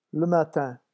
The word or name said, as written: le matin